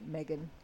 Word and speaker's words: Meghan: My name's spelled with an H, so it's phonetically Megan. – Megan. – Yeah, because the double consonants make it a hard E. Megan.